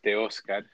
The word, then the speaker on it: Teoscar: Teoscar Hernandez. – Yeah, Teoscar. – Ooh, Teoscar. Ooh, okay, I did pretty good. – If you want to do it, not like a gringo, it's Teoscar.